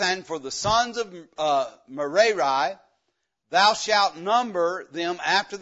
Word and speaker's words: Merari: It's Merari. That's how you say it. Merari.